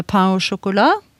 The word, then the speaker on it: pain au chocolat: Eh, pain au chocolat, som nå selges på enhver eh, kaffebar, og som uttales sånn, iallfall hvis man skal si det på noenlunde fransk. Og ikke 'chocolate pain', som jeg hørte her forleden.